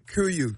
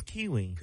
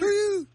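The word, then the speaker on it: KUIU: I thought it was Kiwi. – Kuyu. – How do you say that? – Y'all, companies make it. – Kuyu.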